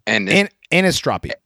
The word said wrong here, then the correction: anisotropy: No, anisotropy. – Anisotropy. There's another... Oh, I fucking missed a syllable.